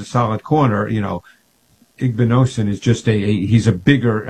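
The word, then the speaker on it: Igbinosun: So, you know, the Ohio State kid, is it Igbinosen? How do you pronounce his name officially? – Igbinosen.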